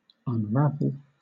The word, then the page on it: unravel: British English